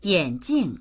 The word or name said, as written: yan3jing4